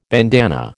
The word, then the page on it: bandanna: American English